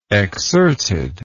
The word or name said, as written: exserted